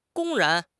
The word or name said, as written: 公然